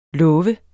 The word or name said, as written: lov